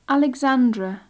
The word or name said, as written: alexandra